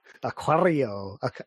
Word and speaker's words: Aquario: You got to roll your R's, but otherwise it's pretty good. – Yeah. Aquario.